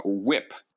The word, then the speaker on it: WHIP: There's a whip is a stat. W-H-I-P. – Whip. It's pronounced whip.